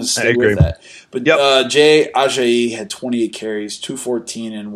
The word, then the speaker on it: Ajayi: Now, I've heard his name on television several times, and I think we've been saying it wrong all along. – I don't care. – I was not going to change it. as he was called in CBS, Jay Ajayi.